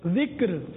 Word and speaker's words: ذکر: فرمانے باپا، علم، ذکر، فکر، شکر، صبر، یہ تلفظات درست ہیں.